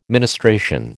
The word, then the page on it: ministration: American English